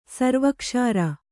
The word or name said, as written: ಸರ್ವಕ್ಷಾರ